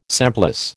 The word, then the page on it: semplice: American English